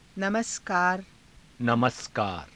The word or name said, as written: namaskaara